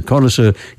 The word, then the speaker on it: connoisseur: Connoisseur is the standard accepted English pronunciation.